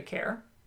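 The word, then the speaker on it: Kerr: Care, we're going to say Care, because that looks like what it's pronounced.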